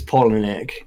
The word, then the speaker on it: Palahniuk: It's Palahniuk.